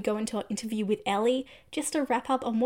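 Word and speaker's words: Elle: Yes, it's spelt like L, but pronounced Ellie.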